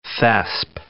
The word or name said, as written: thesp